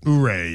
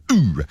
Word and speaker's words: Ouray: Ouray, yeah. – Yeah, Ouray.